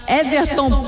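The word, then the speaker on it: Whevertton: Everton Pop 17. – W-H-E-V-E-R-T-T-O-N. E Pop, P-O-P.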